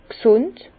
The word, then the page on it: g' sùnd: Bas Rhin; Herrlisheim